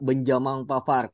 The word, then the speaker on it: Benjamin Pavard: Nah, gimana lo akhirnya ngelihat rumor Benjamin Pavard yang katanya diisukan masuk ke radar beberapa klub besar termasuk Barca dan juga MU? – Iya, menurut gue BTW bacanya harus ala Prancis ya, Benjamin Pavard.